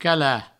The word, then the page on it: calar: cala